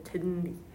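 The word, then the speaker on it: Zenly: 아, Z-E-N-L-Y. 젠리예요, 젠리.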